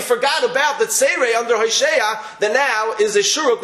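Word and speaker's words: Hoshea (הושע): Hoshea is spelled Hey Vav Shin Ayin. What nekudas are under the shin? Atzeire, there are two dots. Hoshea.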